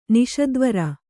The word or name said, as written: ನಿಷದ್ವರ